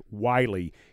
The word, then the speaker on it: Whyle: and the guy that they select is Josh Wiley. It's spelled W-H-Y-L-E, but it is pronounced Wiley.